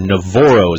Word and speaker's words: NaVorro: decision date for four-star linebacker Navorro.